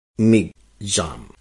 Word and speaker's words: Michaung: तो यह है मिगजाम, MICHAUNG।